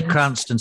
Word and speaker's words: Cranstoun: I should just say Cranston, spelled C-R-A-N-S-T-O-U-N. That's how it's spelled. – That's correct, yes.